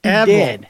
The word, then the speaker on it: Evel: She actually sent the pronunciation. It's Evel. It's Evel. – Evel. – Evel. And but it's spelled E-V-E-L. And I don't care, but if you have a weird name, it's whatever I say it is. So it's evil.